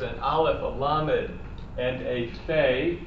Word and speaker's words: Aleph: The first hint is that, unlike the rest of the alphabet, Aleph is the only silent letter. All the other letters of the alphabet have a physical sound associated with them. Aleph,